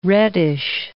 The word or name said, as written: reddish